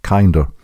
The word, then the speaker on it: QYNDR: The potential for a drinkable, snortable, inhalable vaccine comes courtesy of US Specialty Formulations, a company which has produced the QYNDR, Kinder vaccine. If Kinder is a bit of a consonant-rich mouthful, then be informed that the official pronunciation is Kinder.